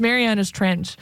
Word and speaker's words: Marianas Trench: It's Marianas Trench.